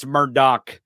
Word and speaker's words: Murdaugh: But here's the moment where Alec, and it's spelled Alex, but it's Alec, and it's spelled Murdaugh, but it's Murdaugh.